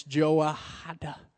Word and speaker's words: Jehoiada: It really matters how to pronounce it. So I took time to study it, and it's Jehoiada.